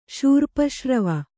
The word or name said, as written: ಶೂರ್ಪಶ್ರವ